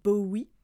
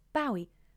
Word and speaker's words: Bowie: as a French person, for years I used to say David Bowie, not knowing that it was actually meant to be pronounced Bowie, but now I know better.